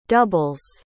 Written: doubles

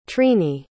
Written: trini

